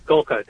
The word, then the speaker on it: Golka: And now I believe it's Bishop James, is it Golka? Is that how you say his name? – Golka, that's correct. Yep.